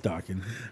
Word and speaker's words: Dokken: You're docking, actually. Man. Dokken. – Please tell me I'm not mispronouncing it because Dokken sounds stupid. – No, it's Dokken.